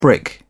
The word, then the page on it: brick: British English